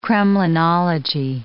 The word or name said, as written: kremlinology